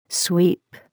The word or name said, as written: sweep